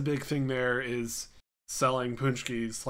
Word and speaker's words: paczki: But the other thing I remember from Fat Tuesday and Mardi Gras and stuff are called punchkis. – Okay. – I don't know if you heard of them. – Nope. – Yeah. If you pull up on Google, it's spelled PACZKI.